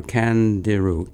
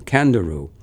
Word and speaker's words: Candiru: Let's see, you pronounce that C-A-N-D-I-R, Candiru. Candiru.